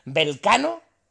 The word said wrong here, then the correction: Belcanos: Belcanos. – Vale, Belcanos. Bien, no es malo, ¿eh?